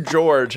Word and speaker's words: George: My name, George, has a soft G.